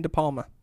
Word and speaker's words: De Palma: De Palma? Is that how you say his name? – Yes, Brian De Palma.